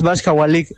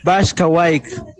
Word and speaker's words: Vascahuayc: No es Vascahualic, es Vascahuayc. Yo soy de pueblo, amigos, yo soy maya.